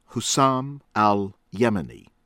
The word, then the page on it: AL-HAIDARI, FARAJ: fah- RAHJ ahl h-eye- DAH- ree